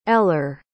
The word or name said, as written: eller